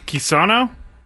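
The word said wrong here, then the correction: Quilici: L-I-C-I. – Oh, Quillacy. – Quillacy. – Yeah, I'm going Frank Quillacy.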